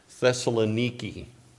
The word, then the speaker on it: Thessaloniki: I thought it would be appropriate, and I find it interesting that the church in Thessalonica, just a sidebar here, actually you pronounce the word in the original language, Thessaloniki.